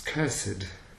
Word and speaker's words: cursed: So this here we have an R and the R still isn't pronounced, right? – No. Cursed. – It really gets taken into the vowel. It affects the vowel. – Yeah, you're right, because it's not cursed. It's cursed, and on a single note it would be cursed. No, it's not pronounced.